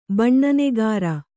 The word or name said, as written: ಬಣ್ಣನೆಗಾರ